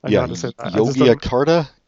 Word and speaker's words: Yogyakarta: Yogyakarta, Indonesia.